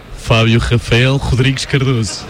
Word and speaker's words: Fabio Rafael Rodríguez Cardoso: Fabio Rafael Rodríguez Cardoso. Está bien así.